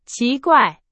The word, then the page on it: 奇怪: Chinese